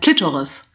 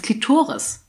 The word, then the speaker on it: Klitoris: Das heißt Klitoris. Ich will es nicht verarschen, das heißt Klitoris. Also die Betonung mehr auf dem O.